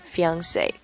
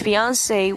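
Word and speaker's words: fiancé: 这个后面的 double e 和 e 它不念是 e 啊，也或也不是念作 a，它是念作 a 啊，fiance fiance。 Alright, here's here's the example as well. A 45 year old man and his fiancee were celebrating a family holiday in their apartment.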